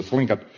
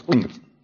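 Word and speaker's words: Tlingit: I was talking to the Thlinkit witch doctor one time. As a matter of fact, I didn't pronounce that properly. I'm sorry, it's Thlinkit.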